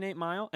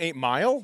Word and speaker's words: 8 Mile: Oh, you haven't seen 8 Mile? Why are you pronouncing it like umbrella, like you're from the South? It's 8 Mile. – Mile. 8 Mile. – Yeah. Not 8 Mile.